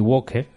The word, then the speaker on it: Walker: O Nami Walker, si lo pronunciamos bien, que la L es muda.